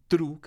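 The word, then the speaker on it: Triệu: Es Lady True, que se escribe Trieu.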